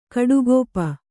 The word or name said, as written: ಕುಡಿಗಪ್ಪು